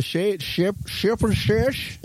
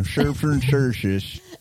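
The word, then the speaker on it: Stjepan Šejić: Oh, the Shepherd Shish. That's actually how you say his name. Shepherd Shish. – Oh, yeah, definitely that. – Yeah, Stjepan Sejic, I think.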